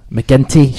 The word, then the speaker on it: McGinty: Big thanks to Keith McGinty. McGinty, shit, every single time I say his name wrong. Thanks to Keith Hard G.